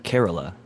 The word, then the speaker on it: Kerala: I think it's so, Kerala, not to be like too India specific, but somebody corrected me, it's Kerala is the way that you pronounce that little area in India,